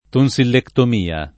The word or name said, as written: tonsillectomia